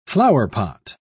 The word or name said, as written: flowerpot